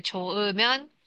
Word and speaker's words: 좋으면: 它遇到圈圈加母音的时候，它会脱落，所以 초 으 면 它会 허 不发音。